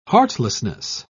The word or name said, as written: heartlessness